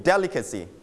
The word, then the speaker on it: delicacy: delicacy repeat after me delicacy straight on the first syllable